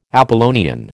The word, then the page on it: Apollonian: American English